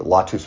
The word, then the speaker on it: latus: It means the latus with a long A, which means to carry.